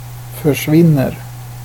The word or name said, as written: försvinner